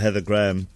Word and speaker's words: Heather Graham: Well, I think she was off duty, and then she heard that we'd binned her for Heather Graham. Graham. Did Heather Graham say that I said her name correctly? – Yes. – Yes, she did.